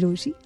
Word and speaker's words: Rochie: मेरा नाम है मिर्ची रोशी, नाम की स्पेलिंग है ROCHIE, एंड ऑनेस्टी, जिसके पास लव है ना उसके पास सब है, एंड आई लव यू लोथ।